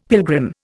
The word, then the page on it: pilgrim: Indian